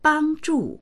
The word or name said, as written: bang1zhu4